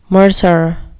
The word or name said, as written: mercer